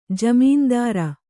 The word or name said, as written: ಜಾಮೀನ್ದಾರ